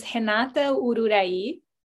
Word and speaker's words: Renata Ururahy: So my name is Renata Ururahy. We would say that in Portuguese.